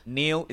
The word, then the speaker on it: Neel: So, Neil is spelled N-E-E-L.